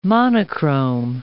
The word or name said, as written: monochrome